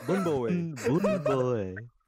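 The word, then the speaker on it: Bún bò Huế: Did you say it right? Is that there? Did I say it right, guys? – No, he did not. – Bun Bo Hue. – Bun Bo Hue. – I'll say it. Bun Bo Hue.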